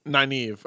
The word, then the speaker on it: Nynaeve: Nynaeve? – Nynaeve.